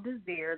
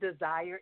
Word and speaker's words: Desir: Yes, Dr. Renee, it's Armelle Desir. The last name is like desire. It's French. Actually, intention does mean desire, but just the E is silent, so it's Desir.